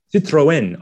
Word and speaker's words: Citroën: I think I said Citroen, and I'll go Citroen. I hope that's Citroën. I hope that's better.